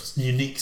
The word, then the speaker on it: UNIQ: One of their top specification, unique selling points is, inverted commas, sit anywhere, close, unique Q sound. So I think it's supposed to be unique sound, but it's spelled U-N-I-Q sound. Unique.